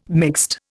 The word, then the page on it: mixed: Indian